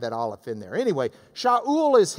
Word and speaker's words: Shaul: Because the Greeks didn't have an SH sound. They couldn't have even pronounced his name. He gets out in the Greek world and they're like, how do you say it? They don't have a sh sound in Greek. So they just do S, sa. So Shaul becomes Saul, Saul, we read it.